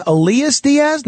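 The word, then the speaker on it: Elias Díaz: That's how he pronounces his name, Elias Diaz, not Elias.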